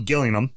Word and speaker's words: Gillingham: Gillingham, spelled Gillingham, G-I-L-L-I-N-G-H-A-M, but it's pronounced Gillingham. And of course, how British is this? What is their nickname? – The Gills instead of the Jills. Okay. You would think it's Gillingham. You would think they call themselves the Jills, but no, they call themselves the Gills.